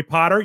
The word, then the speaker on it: Potter: My name is Jerry Potter. Yes, it rhymes with the boy wizard, host of the Social Media Marketing Talk Show, a Social Media Examiner production.